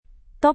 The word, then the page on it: top: Indonesian